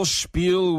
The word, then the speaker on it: spiel: I can't believe his whole spiel was bogus. – 맞습니다. S-P-I-E-L. 발음이 spiel. 아니면 spiel도 되는데 보통 spiel로 많이 해요.